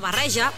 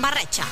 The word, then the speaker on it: barreja: En comptes de pronunciar barreja, pronuncien barretxa.